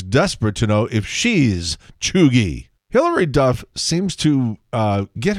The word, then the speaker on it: cheugy: C-H-E-U-G-Y, pronounced Chuggy.